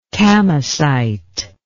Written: kamacite

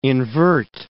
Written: invert

